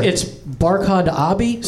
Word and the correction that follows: Abdi: Barkhad, you got right, but it's Abdi.